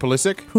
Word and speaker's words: Pulisic: Enner Valencia, Christian Pulisic.